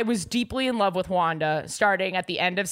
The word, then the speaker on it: Juana: J-U-A-N-A. – Wanda. – But pronounced Wanda.